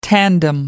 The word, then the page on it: tandem: American English